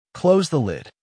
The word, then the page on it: close the lid: American English